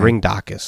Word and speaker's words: Ringdocus: The Ringdococcus. – Excuse me? – The Ringdococcus. – Okay. – Ringdocus, sorry. I'm adding a syllable in there.